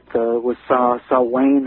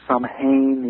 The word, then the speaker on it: Samhain: And of course, all of the rituals associated with Samhain or Samhain, it's spelled, S-A-N-H-A-I-N. All of those rituals then passed on into the northern European cultures, through England, and then finally into this country.